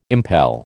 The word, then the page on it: impel: American English